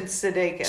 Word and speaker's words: Sudeikis: Jason Sudeikis.